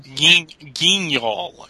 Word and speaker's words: Guignol: Guignol is what I have heard. Grand Guignol.